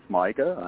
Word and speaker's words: Micah: I am calling from the beautiful coastal area of South Carolina, and thank you for the correct pronunciation. Ever since that Paranormal Activity movie from a long time back, you can kind of tell whenever people see my name on paper because they want to say Micah. – Really? M-I-C-A-H, quite often. – Right? – M-I-C-A-H, the Old Testament spelling.